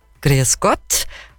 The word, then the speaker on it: Grüß Gott: Grüß Gott? Habe ich das so richtig gesagt? – Ja. – Hat schon ganz gut passt. – Mehr E als wie A.